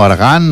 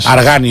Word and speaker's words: Argan: Argan, jo crec que és Argan. – Argan, sí, perquè quan has dit Arjan jo m'ha sonat una mica així estrany, però dic que el millor és que es pronuncia així d'aquesta manera, no ho sé. Nosaltres més popularment el coneixem per Argan.